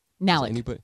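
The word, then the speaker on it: Nalick: I can't remember if her last name is pronounced Anna Nalik or Nalik. – Nalik. – Is it Nalik? Okay. Mm-hmm.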